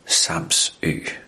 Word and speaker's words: Samsø: Men det er jo ikke helt rigtigt udtalt. I virkeligheden, så hed øen jo ikke Samsø. Den hed Samsø. Samsø.